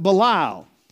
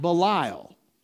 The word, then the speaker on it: Belial: All my ministry, I've pronounced it Belial. And Alexander Scorby pronounces it Belial, with a long B. I've always pronounced it with a long I, Belial.